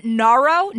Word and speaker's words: noro: So it's actually norovirus, N-O-R-O, norovirus. – What am I saying? Am I saying neuro? – Yeah. – You're like, and this is why you're not an infectious disease expert. So I'm saying, how do you say it? Noro? – It's noro, N-O-R-O. – Like Nora, but noro.